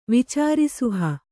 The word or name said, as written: ವಿಚಾರಿಸುಹ